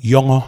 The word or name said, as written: Yawngaw